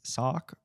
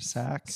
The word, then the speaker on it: Sauk: They are yes, S-A-U-K, pronounced S-A-C. I'm not really big on phonetics. I did do speech, sure. But I think it's sock or sack.